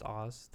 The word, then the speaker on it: AHZ: Yeah, we were a little confused about how to say the name of this band. – A-H-Z. – Yeah, the band is A-H-Z, and we think it's pronounced Oz.